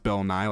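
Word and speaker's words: Bill Nighy: I think it's pronounced Bill Nye, like Bill Nye the Science Guy, for Living.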